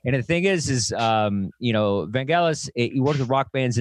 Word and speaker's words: Vangelis: Directed this movie, and the music is by Vangelis, not Vangelis. I always say it wrong.